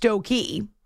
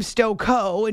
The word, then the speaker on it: Stokoe: First of all, Anthony says he says the S-T-O-K-O-E title for the farm in Rochester is pronounced Stokey. So that's what he says, Stokey. Daniel finds us on Twitter and says, regarding the name Stoko, and he pronounces it Stoko, like Coco, I've only ever heard the name once before. It's in sports. Bob Stoko was the manager of the Sunderland soccer team that won the FA Cup in England in 1973. His name rhymes with Coco.